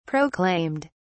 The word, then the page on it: proclaimed: British English